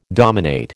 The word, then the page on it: dominate: American English